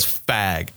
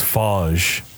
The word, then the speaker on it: Fage: You're just driving down the highway, and all of a sudden there's this huge sign in bright red neon letters that just says Fag. – I think it's Fage. – Okay. Well, it doesn't look like it. That's not how it's spelled.